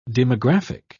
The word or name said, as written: demographic